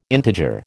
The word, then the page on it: integer: American English